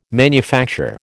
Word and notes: manufacture: American English

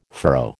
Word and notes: furrow: American English

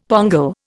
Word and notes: pongal: Indian

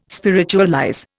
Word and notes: spiritualize: Indian

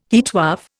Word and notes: heatwave: Indian

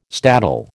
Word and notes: staddle: American English

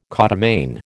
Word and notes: codomain: American English

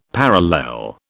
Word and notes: parallel: British English

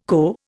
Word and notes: co: Indian

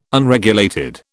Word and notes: Unregulated: British English

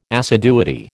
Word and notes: assiduity: American English

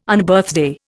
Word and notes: Unbirthday: Indian

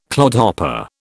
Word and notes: clodhopper: British English

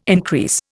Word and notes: increase: Indian

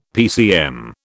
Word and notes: PCM: British English

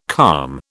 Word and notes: calm: British English

